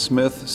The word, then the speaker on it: Cmith: Still pronounced Smith, C-M-I-T-H.